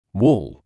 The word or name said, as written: wall